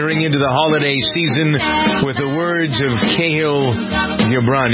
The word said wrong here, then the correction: Khalil: Do you ever pronounce the name Khalil? Khalil. Remember him from when I was in college.